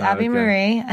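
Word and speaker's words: Abi-Maria: So how do you say it? – Well, it's Abby. It's Abby Marie.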